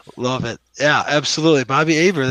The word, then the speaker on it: Hebert: Bobby Hebert. You know, that's right. – If some people don't pronounce it Herbert, they pronounce it Hebert, like Bobby Hebert.